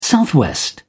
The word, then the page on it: southwest: British English